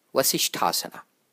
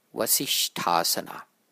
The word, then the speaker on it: Vasishthasana: Vasishthasana has two S's in it, but they are pronounced differently. The first S, vas, is pronounced like the S in the English word sip. The second S, shtha, is pronounced like the S in the word shout.